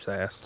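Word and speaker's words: Sasse: Yeah, it's Sass.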